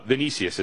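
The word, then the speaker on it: Vinícius: By the way, Vinicius is how I should probably pronounce it properly.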